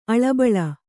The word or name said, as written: ಅಳಿಬಾಳು